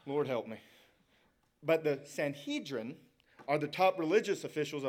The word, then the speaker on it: Sanhedrin: The council is the Sanhedrin. I always say that wrong. Blair always corrects me. Sanhedrin. The Sanhedrin, thank you.